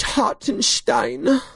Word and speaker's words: Hartenstein: Is it Steen or Stein? – It's Hartenstein.